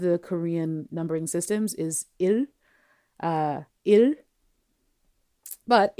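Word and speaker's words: il (일): It's like ill, but you're not really touching the tip of your teeth. You're not touching your teeth with the tip of your tongue, excuse me.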